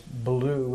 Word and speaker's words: Bilyeu: She's Wolf with two O's, and my name is Blue, which is spelled B-I-L-Y-E-U.